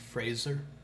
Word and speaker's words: Fraser: His last name is pronounced Fraser. Fraser.